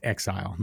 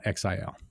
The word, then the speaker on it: Exile: It's pronounced Exile, not X-I-L.